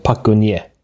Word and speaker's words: Park Geun-hye: Det som har hänt är att Sydkoreas president, som vi kan kalla för Park Geun-hye, i själva verket så uttalas det namnet annorlunda. På koreanska ska vi säga, det uttalas Park Geun-hye ungefär. Men jag kommer inte kunna uttala alla koreanska namnen på koreanskt sätt, därför uttalar jag dem på svenskt sätt istället.